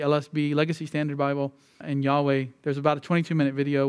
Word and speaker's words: Yahweh: But Jehovah is not the proper pronunciation. It's Yahweh, which kind of is funny because you think of this cult, Jehovah's Witnesses.